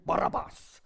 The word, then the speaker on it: Barabbas: And in my home language, we would call him Barabbas. That's quite a strong guttural word.